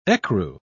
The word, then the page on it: ecru: British English